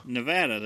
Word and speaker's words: Nevada: Nevada, that is how you say it.